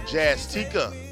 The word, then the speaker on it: Jazz Tika: As I add to a little Jazz Tika, rhymes with Eureka.